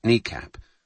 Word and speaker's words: NEACP: At Andrews Air Force Base, the National Emergency Airborne Command Post, NEACP, pronounced NECAP,